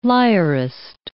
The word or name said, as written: lyrist